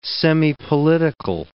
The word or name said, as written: semipolitical